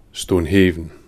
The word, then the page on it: Stonehaven: /stoʊnˈheɪvən/